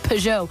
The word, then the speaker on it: Peugeot: Just so quick, isn't Peugeot. – It? – How you pronounce it, Peugeot.